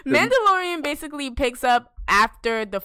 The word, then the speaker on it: Mandalorian: Mandalorian.